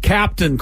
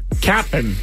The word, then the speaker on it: Cap'n: And by the way, I'm pronouncing that correctly. It is not Captain Crunch. – Cap'n. – It's Cap apostrophe N.